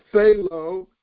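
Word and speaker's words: thelo (θέλω): And in the Greek is thelo, T-H-E-L-O, long O.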